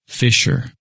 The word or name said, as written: Fisher